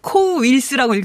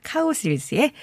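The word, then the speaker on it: Cowsills: 아니, 첫 곡 가수 이름을 뒤집어 써놓는 바람에, 예, 코우 윌스라고 읽을 뻔했어요. 카우스 윌스의 The Rain, The Park and Other Thing 이었습니다.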